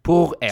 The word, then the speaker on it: Pourelle: r P-O-U-R-E-L-L-E, Pourelle. Porrell. – Mm. – Hur säger man det? Porrell. Porrell. – Så du säger ju rätt bara att du har liksom amerikanska accent när du säger det.